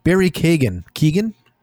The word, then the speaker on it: Keoghan: Well, so Barry Keoghan, that's how you say his name, is at the end of the film.